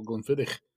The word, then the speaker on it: Glenfiddich: Glenfiddich.